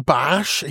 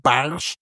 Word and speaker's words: Barge: Wir werden ihn jetzt in eine kleine Barge. Ich habe es beim letzten Mal noch Barge genannt, weil ich das so gelesen hatte. Und dann habe ich gelernt, es wird gar nicht Barge ausgesprochen, sondern Barsch. Wie der Barsch zum Angeln, aber ist gar kein Barsch, sondern eine Barge.